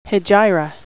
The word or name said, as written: hegira